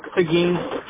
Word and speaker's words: Seguin: Seguin. – Seguin. That's where you're located at the moment, correct? – Yeah, so we do like 30, 40 mile radius around Seguin.